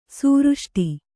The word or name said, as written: ಸುವೃಷ್ಟಿ